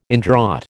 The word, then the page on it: indraught: American English